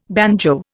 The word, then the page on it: banjo: Indian